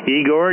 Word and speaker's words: Igor: Igor, you are 5x9 in Ohio.